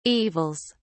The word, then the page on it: evils: British English